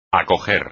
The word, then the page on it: acoger: espagnol